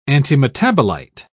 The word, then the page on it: antimetabolite: British English